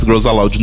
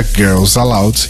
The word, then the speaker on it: Girls Aloud: Percebam a diferença da pronúncia Girls Aloud, Girls Aloud.